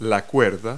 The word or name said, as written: rope